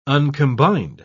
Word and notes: uncombined: British English

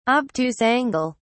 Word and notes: obtuse angle: British English

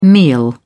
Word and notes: meal: British English

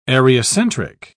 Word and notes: areocentric: British English

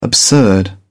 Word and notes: the absurd: British English

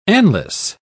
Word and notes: anlace: British English

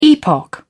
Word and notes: epoch: British English

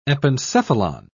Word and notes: epencephalon: British English

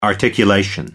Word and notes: articulation: American English

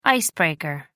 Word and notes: ice breaker: American English

